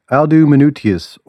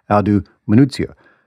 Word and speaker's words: Aldo Manuzio: Yes, a single dude, Aldo Manutius, or as Florence more correctly pronounced it, Aldo Manuzio,